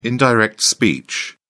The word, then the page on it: indirect speech: British English